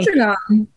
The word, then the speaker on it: Sandringham: Sandringham.